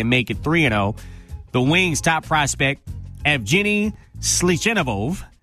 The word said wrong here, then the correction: Svechnikov: is, I probably just butchered that name, Isaac. You want to take a stab at that? Take a stab at that name. – Svechnikov? – Svechnikov. Yeah. Okay, you cheated.